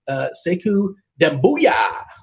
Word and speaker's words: Sekou Doumbouya: How about the conversation of Christian Wood versus Sekou Doumbouya? – Nice pronunciation there.